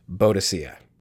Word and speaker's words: Boadicea: And uh, there's a couple different ways of pronouncing it. I'll say in the traditional kind of British way first, and that is Boadicea.